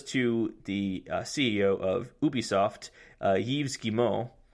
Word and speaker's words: Yves Guillemot: I'm just telling you how to correctly pronounce his name. Yves Guillemot.